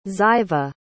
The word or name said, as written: ziva